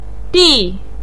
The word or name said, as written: di4